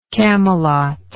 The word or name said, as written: camelotian